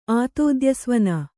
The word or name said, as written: ಆತೋದ್ಯಸ್ವನ